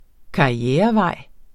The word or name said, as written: karrierevej